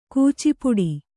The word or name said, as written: ಕೂಚಿಪುಡಿ